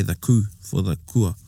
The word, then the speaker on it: kua: So, you know, South Taranaki is like for kua, K-U-A. So North Taranaki, our old people used to pronounce it kua, right? Kua. More of a K-O-A. – Oh, yeah. – Kua. Kua aere, you know. And then down south, ku aere. They don't say the A, ku aere.